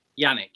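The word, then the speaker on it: Janick: Is it Yannick? – Yannick, yeah.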